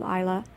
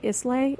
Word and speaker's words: Islay: I think that these are whiskeys that come, as you mentioned, they come from an island called Isla. Some people mispronounce it and say Islay. It's actually Isla. It's spelled Islay, should you see it on a bottle.